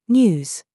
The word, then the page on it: ZOOS: British English